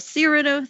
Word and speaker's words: Cyrano: Jake wants to impress Marley, and it's the whole Cyrano thing because that's how you say it.